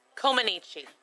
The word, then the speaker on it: Comaneci: Which is, you didn't say it right. That's why you like saying it so much, because you said it wrong. – Say it for me, encyclopedia. – Comaneci. – Comaneci. – There you go.